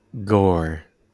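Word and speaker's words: Gorr: His name rhymes with Thor. – Core. – So close. It's Gore.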